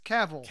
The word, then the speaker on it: Cavill: How do you say his last name? – Cavill. – It's Cavill. – Cavill. Henry Cavill.